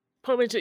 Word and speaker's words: portmanteau: Was it portmanteau? Portmanteau? – Portmanteau, yes.